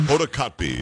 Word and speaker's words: Hoda Kotb: Hoda Kotb? Is that how you say her name? – Hoda Kotb, yeah. Hoda Kotb.